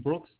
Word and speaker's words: Brooks: First name Brooks, B-R-O-O-K-S. Last name Gault, G-A-U-L-T.